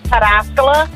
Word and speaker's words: Pataskala: So, like, Pataskala is Pataskala.